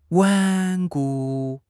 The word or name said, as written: 顽固